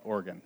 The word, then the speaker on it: Oregon: People call it something different, but let me tell you, the people that live there call it Oregon. So we're going to say that's the right way to say it.